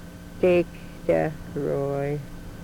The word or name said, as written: Tektah We-Roy